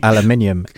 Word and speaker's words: aluminium: Or aluminium. And yes, which is the correct way to pronounce it. I mean, because for those of us that that watch Top Gear when the original three were on, that's how you pronounce it.